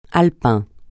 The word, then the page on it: alpin: francais